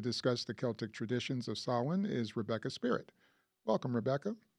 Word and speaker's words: Samhain: From sunset on October 31st to sunset on November 1st is the traditional celebration of Samhain, which is spelled S-A-M-H-A-I-N and looks like Samhain, but is pronounced Samhain.